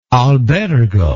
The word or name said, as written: albergo